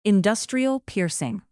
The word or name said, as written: industrial piercing